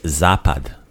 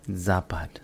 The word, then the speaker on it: Zapad: The word for west is Zapad. – Zapad? – Right. So the sun sets in the west, right? Mm-hmm. Zapad. – Zapad.